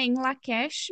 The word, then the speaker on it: In Lak'ech: uh, outra expressão, não impressão, que a gente já falou algumas vezes, que é o Inlakesh. É, é, ela tem essa hum, pronúncia, né, em Lakesh, mas acho que eu já ouvi uma outra forma que eu não me lembro como é que é.